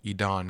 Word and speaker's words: Edan: His name is spelled E-D-A-N. The way he phrased it was, it's like email, but Edan. But, you know, Idan, because it's Hebrew.